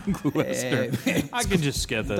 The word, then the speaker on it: Gloucester: Is it pronounced a Gloucester or a Gloucester? – I think it's Gloucester. – It's spelled Gloucester, but they pronounce Gloucester, just like a Worcester.